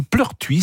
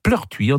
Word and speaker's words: Pleurtuit: Eh bien, à Pleurtuis, en Ille-et-Vilaine, Pleurtuis, ça s'écrit P-L-E-U-R-T-U-I-T, Pleurtuis, en Ille-et-Vilaine, la mairie nomme le marché de Noël le village enchanté.